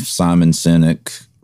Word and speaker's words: Simon Sinek: I think about the book, I believe Simon Sinek wrote it.